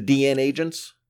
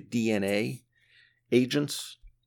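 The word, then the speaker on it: DNAgents: This is from the Eclipse series by Mark Evanier, the DN Agents. I know a lot of people pronounce it the DNA Agents, but there is only one A. It's DNA Gents. So I say the DN Agents.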